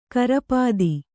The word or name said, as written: ಕುರೂಪತೆ